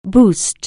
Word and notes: boost: anglais

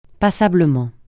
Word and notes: passablement: francais

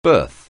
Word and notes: birth: anglais

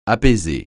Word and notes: apaiser: francais

